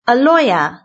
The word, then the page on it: a lawyer: British English